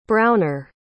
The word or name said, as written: browner